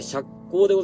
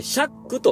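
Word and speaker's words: 赤口: 赤い口と書いて釈光でございます。えー、シャックという場合もございます。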